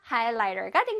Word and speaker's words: highlighter: 그래서 highlighter가 된 겁니다. 한번 발음해 볼까요? highlighter. highlighter, high 부분을 아주 길게, high, 그 다음에 li 부분도 길게, highlighter, highlighter, 끝에 er 부분은 부드럽게 살짝 톡 떨어뜨려 주세요.